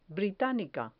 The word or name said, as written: britanica